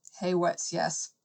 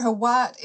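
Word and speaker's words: Hawat: Thufir, I can never pronounce his name. One second, let me just look this up. Sorry. – No, it's fine. It's fine. – Thufir Haywat. – Haywat, yes.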